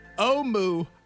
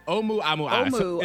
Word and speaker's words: Oumuamua: It's pronounced Oumuamua. Oumuamua. It's Hawaiian. – Oumuamua.